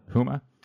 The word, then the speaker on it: Houma: One is from your recent series, The Silent General, It's a picture of the burning of a sugarcane field in, I'm going to mess up this pronunciation, sorry, Houma, Louisiana? – Houma.